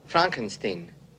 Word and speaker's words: Frankenstein: Frankenstein. You're putting me on. No, it's pronounced Frankenstein. Do you also say Frederick? No, Frederick. Well, why isn't it Frederick Frankenstein? It isn't. It's Frederick Frankenstein.